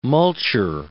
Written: multure

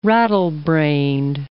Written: rattlebrained

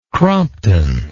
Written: crompton

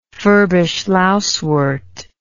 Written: furbish lousewort

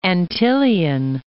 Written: Antillean

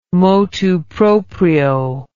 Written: motu proprio

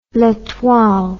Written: letoile

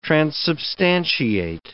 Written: transubstantiate

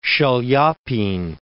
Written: Chaliapin

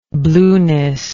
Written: blueness

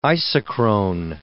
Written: isochrone

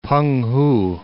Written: P'eng-hu

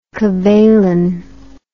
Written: Cavaillon

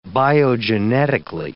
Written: biogenetically

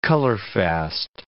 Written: colorfast

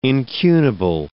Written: incunable

